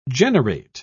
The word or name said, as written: generate